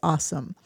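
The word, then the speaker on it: AuSM: It's A-U-S-M, pronounced awesome.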